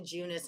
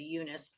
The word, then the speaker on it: Junis: They said it's definitely Junis, and I've always said Eunice, but that's an Anchorman thing. Like if there's a J anywhere, I'm usually going to make it a soft J, because I've watched Anchorman way too many times.